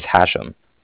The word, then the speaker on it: Hashem: My name is Hashem.